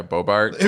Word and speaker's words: Boebert: Yeah, Boebert.